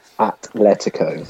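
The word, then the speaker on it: Atlético: Atletico.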